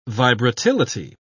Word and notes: vibratility: British English

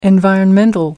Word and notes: environmental: American English

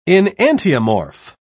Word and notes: enantiomorph: British English

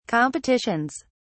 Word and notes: competitions: British English

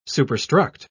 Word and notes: superstruct: British English

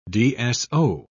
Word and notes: dso: British English

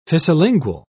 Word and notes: fissilingual: British English

